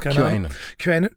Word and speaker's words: QAnon: Dieser Kanan oder so, dieser QAnon, wie spricht man das aus? – QAnon hat jetzt behauptet, dass es wieder losgegangen ist, weil es werden jetzt hochaufgelöste Bilder von Pizzen bei Etsy verkauft.